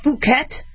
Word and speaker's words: Phuket: Make sure you say it long U, because if it's short U, it can get us in trouble on air. But it's Phuket. That's also a B strain.